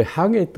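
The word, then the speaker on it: 破夏: 夏を破る、破る夏と書いてありますね。これ、ハゲと読みます。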